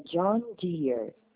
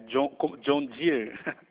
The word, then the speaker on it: John Deere: O nome da empresa que eu trabalho se pronuncia John Deere. – Ah, correto. John Deere.